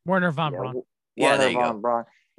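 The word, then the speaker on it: Wernher von Braun: Werner von Braun, if I said that right. – Werner von Braun.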